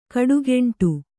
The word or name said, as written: ಕಿಡಿಗಂಡಿ